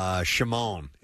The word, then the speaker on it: Shimon: Shimon is how you spell that lead singer's first name, but it isn't pronounced that way. It was Shim.